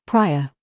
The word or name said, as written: PIPE